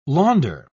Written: launder

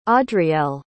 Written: audrielle

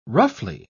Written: roughly

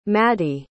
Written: Maddi

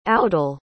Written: audel

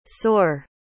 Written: saur

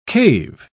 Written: cave

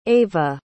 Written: ayva

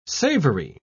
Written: savory

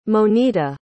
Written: monita

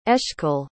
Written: eshkol

